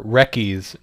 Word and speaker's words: Wreckies: Remember, Wreckies, not Reese's. – Even though it sounds like Reese's and it makes me want Reese's time.